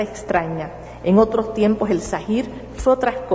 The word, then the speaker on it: Zahir: En árabe, la voz Zahir significa lo visible o manifiesto, se pronuncia Zahir.